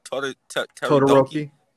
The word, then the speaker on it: Todoroki: Todoroki.